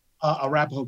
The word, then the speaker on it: Arapahoe: Is it Arapahoe Community College? What am I saying that right? – Arapahoe Community College.